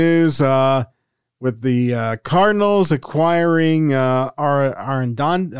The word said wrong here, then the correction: Arenado: you talk talking about Nolan Arenado?